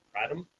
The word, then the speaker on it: Kratom: Yeah, because they sell like Delta 8 and hemp-based products along with Kratom.